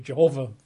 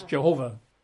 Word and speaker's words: Jehovah: I'm 93%, roughly, convinced that it's Jehovah. Probably pronounced Jehovah, with the emphasis on the last syllable, Jehovah, rather than Jehovah. But in English, we say Jehovah, so that's what I use.